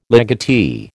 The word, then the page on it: legatee: American English